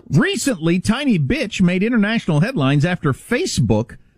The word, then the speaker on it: Bitche: I was going to pronounce it Biche because it's B-I-T-C-H-E, but the E is silent. It's actually pronounced Bitche, I believe.